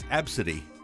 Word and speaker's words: Abcde: Southwest Airlines employees who ridiculed a young girl whose name is spelled, her first name is spelled A-B-C-D-E. Her mother pronounces it for her because she can't pronounce it. It's Absidy. And the Southwest employees saw that on her ticket and kind of poked fun.